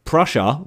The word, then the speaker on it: Prussia: Prussia,